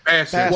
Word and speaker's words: Bassett: Wait, Angela Bassett? Is that how you say it? – Bassett.